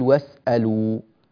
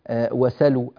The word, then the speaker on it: واسألوا: في قوله تعالى واسألوا ما أنفقتم وليسألوا ما أنفقوا هذه السين ساكنة والهمزة محققة فنقول واسألوا نقول إيه ننطقها واسألوا ونوضح هذا بشكل جيد بحيث إننا لا نقول مثلا وسلوا أو نحو ذلك واسألوا ما أنفقتم وليسألوا ما أنفقه هذه هي قواعد الرواية هذه هي قواعد الرواية.